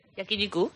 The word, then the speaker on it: Yakiniku: Yakiniku. – Yakiniku, sí.